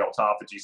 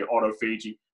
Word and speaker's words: autophagy: So some people say autophagy, some people say autophagy.